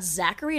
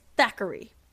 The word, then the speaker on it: Thackeray: The main guy's name is not Zachary. It's Thackeray. – It's Thackeray Binks. – It fucks with me. – Thackeray. – For years, I thought it was Zachary. – It's as if someone was just pronouncing Zachary with a lisp, but that's his name.